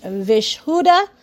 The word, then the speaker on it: Vishuddha: The throat chakra is pronounced Vishuddha, okay, which is the chakra that has the ability to communicate verbally.